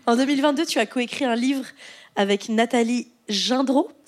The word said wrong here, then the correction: Gendreau: c'est comme ça qu'on prononce Nathalie Gendreau.